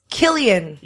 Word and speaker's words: Cillian: A lot of people wrote in, pronounce Killian.